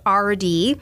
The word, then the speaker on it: Ardee: This is the time where they had a new brand of flour called RD, and it's spelled A-R-D-E-E. So it's like his initials, the letter R and the letter D, but that was the brand of flour in honor of him.